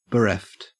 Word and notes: bereft: Australian English

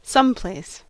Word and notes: someplace: American English

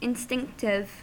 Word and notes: instinctive: American English

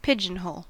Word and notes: pigeonhole: American English

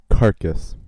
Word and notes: carcass: American English

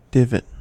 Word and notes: divot: American English

